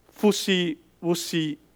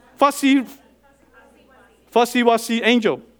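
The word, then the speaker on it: Fuzzy Wuzzy: That's fussy-wussy angel. I intentionally pronounced it wrong to let you correct it. It's a fussy-wussy angel.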